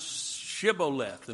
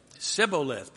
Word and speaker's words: Shibboleth: Can you pronounce Shibboleth? And what would they say? Sibboleth, because they couldn't, for whatever reason, pronounce that SH sound.